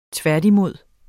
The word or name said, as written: tværtimod